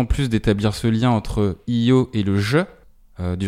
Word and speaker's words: EO: Le nom Eo dans le film se prononce Io, comme on essaie de le faire euh, depuis le début du podcast.